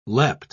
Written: leapt

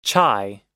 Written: chai

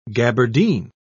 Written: gaberdine

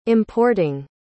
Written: importing

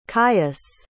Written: kious

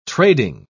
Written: trading

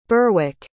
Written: Berwick

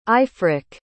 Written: aifric

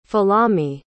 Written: folami

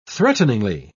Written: threateningly

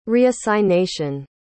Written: reassignation

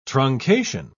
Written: truncation

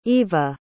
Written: iva

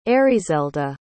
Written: Erizelda

